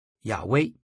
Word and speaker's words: 亚威: 近来的考古发现显示这个词应该发音为亚威，亚威最常被翻译成耶和华，但实际上应该是动词 Haya 的第三人称形式，可以被翻译成他是或是他将是。